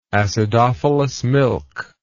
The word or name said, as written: acidophilus milk